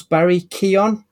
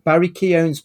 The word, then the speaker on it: Barry Keoghan: I found out that apparently Druig from The Eternals, Barry Keoghan, I believe it's pronounced, he's set to feature as Stanley Merkel, which is a deep-cut character from the Batman universe.